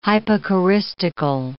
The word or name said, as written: hypocoristical